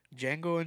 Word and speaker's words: Django: The D is silent. – What? – What? – The D in Django Unchained? – Oh, okay.